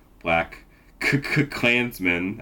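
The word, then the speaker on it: BlacKkKlansman: We will pair a recently released movie, in this case, Spike Lee's newest joint, Black Klansman, I think is how you're actually supposed to pronounce it. Is that correct? – Yes, that's right. Hard K's.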